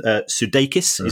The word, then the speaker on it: Sudeikis: But certainly the people involved at Ted Lasso are doing very well, because apparently Jason Sudeikis, is that the correct pronunciation? – Yeah.